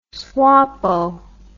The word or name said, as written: swapo